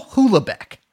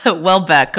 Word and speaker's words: Houellebecq: I'm actually going to pronounce it wrong on purpose. Michael Houellebecq. – No, Houellebecq. Okay. – Well, yeah. – Let's just say Houellebecq.